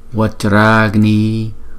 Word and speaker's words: Vajragni: Cho nên theo luật tiếng Phạn, chúng ta không đọc là Vajra Agni, mà chúng ta đọc là Vajra Agni. Đó. Và cách chúng ta đọc câu chú này là như sau: Om Om, nào chúng ta đọc chữ O kéo dài trước rồi mới đóng chữ O lại. Om Vajragni Vajragni, nào chữ A này là chữ A dài.